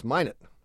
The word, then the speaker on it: Minot: Isn't it from Minot? – Mino? – No, it's Minot. – Well, I know they pronounce it Minot, but isn't it Mino? – They pronounce it Minot.